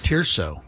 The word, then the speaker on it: Tirso: Tirso. – Yes. – How do you spell that? – T-I-R-S-O.